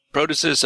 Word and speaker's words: protasis: The apostasis, but not in the prostasis. – Protasis, apodosis.